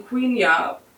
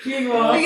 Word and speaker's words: quinoa: From the producers of Shark Week, coming this fall, pronouncing Queenia properly. Did I even do it? – Quinoa.